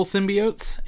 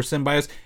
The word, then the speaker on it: symbiotes: I think a takeaway is here, there's multiple symbiotes in this, or symbiotes. If you look it up, it's actually pronounced both ways.